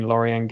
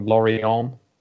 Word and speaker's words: Lorient: It's been both teams to score in 8 of the last 9 Lorient games. I realise I'm saying it wrong. Lorient.